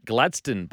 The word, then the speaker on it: Gladstone: Uh, Gladstone is pronounced Gladstone, by the way. No, I know that, mate. I just read it incorrectly, but uh, cheers, appreciate it.